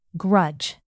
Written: grudge

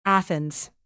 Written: athens

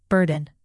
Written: burden

